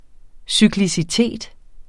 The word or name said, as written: cyklicitet